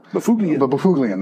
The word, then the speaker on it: Byfuglien: I couldn't say his name. I said it Dustin Byfuglien all the time. – Byfuglien. – That's another one.